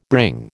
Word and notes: bring: American English

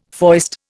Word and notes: foist: Indian

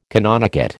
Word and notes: canonicate: American English

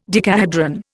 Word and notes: decahedron: Indian

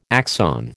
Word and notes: axon: American English

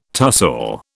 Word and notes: tussore: British English

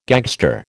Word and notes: gagster: American English

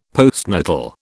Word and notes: postnatal: British English

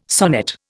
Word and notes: sonnet: Indian

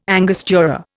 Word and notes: angostura: Indian

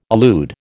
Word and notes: allude: American English

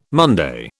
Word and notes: Monday: British English

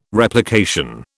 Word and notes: Replication: British English

